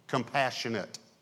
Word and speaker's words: compassionate: So it's an adjective, compassionate. But there is another word that's another derivative of compassion that is spelled exactly the same, but pronounced differently, and it's a verb.